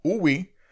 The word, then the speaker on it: wu wei: Uwi eh, se escribe W, U, W, E, I, pero se pronuncia U, i Y D,